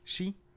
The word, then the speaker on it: Xi: Is that how you say his name? – Xi.